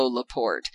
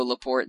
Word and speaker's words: Laporte: His real name is Leo Laporte in the French pronunciation, but they told him that it sounded gay. So he said, "Okay, what if I go by Leo Laporte?"